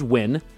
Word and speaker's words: Nguyen: Some Vietnamese people swear it's pronounced Nguyen, with the first N spoken out loud. Some say that you drop the first letter and simply pronounce it as Win, and others insisted that it should be Nguyen.